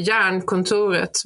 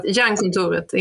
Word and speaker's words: Jernkontoret: Is that what you said earlier, or how would you say it? – I say Jernkontoret, but Iron Office makes sense as well if you translate it. But Jernkontoret in Swedish, yes.